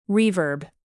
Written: reverb

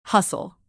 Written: hustle

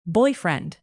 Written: boyfriend

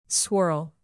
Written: swirl

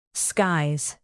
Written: skies

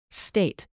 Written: state

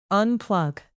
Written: unplug

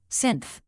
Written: synth

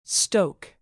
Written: stoke

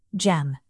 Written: gem

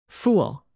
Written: fool

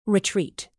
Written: retreat

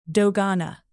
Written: dogana

